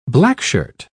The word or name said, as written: blackshirt